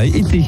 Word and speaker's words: Ethe: Sachez que dimanche 14 et lundi 15 mai, c'est-à-dire demain et après-demain, il y a un podium Fun Radio à été. Ça se prononce comme la saison été. Ça s'écrit E-T-H-E et ça se trouve où ?